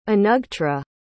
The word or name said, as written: anugtra